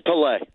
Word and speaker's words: Pelé: And by the way, do you pronounce it Pele or Pelé? – It's Pelé.